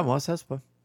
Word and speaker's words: Mos Espa: And so they had built the city of Mos Espa. Am I saying that right? – Yeah, Mos Espa.